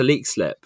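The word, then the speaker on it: Leixlip: But Connor, it's interesting, just going back to Lexlip, which I think I'm pronouncing correctly now.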